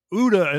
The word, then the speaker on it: Gouda: And I even know it's Ouda and not Gouda, right? – Gouda, yeah. – Oh, but yeah, but you didn't Gouda. Pronounce it. – It's Gouda here.